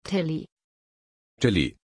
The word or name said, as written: Tilli